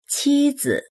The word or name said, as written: qi1zi